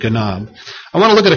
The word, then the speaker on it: ganab: It's the Hebrew word ganab. Ganab. It's spelled G-A-N-A-B.